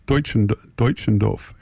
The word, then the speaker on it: Deutschendorf: Oh, Bob Middleton tells me how to pronounce it. It's not it's Deutschendorf.